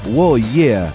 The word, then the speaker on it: Uoiea: Este El nuevo disco de Carca se llama Wo Yeah y se escribe U-O-I-E-A.